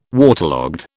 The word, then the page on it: waterlogged: British English